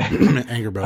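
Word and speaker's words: Angrboda: So Angerboda. – Really, yeah, I don't know how to say her name. – Angerboda, yeah.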